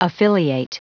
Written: affiliate

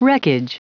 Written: wreckage

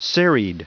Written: serried

